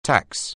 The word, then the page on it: tax: anglais